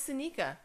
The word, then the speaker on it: Seneca: I always say his name wrong. Damn it. – No, it's Seneca.